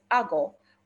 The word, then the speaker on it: ago: And the word led, the Greek word is A G O, pronounced ago, which means to bring, to carry, to guide, to direct.